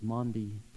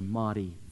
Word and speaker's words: Maundy: And Thursday is known as Maundy Thursday or Maundy Thursday. Both pronunciations are okay.